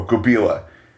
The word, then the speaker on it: Gubila: and that monster is called Gubilon. Let me make sure I'm pronouncing that right. Oh, Gubila. Gubila. G-U-B-I-L-A.